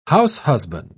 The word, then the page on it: househusband: British English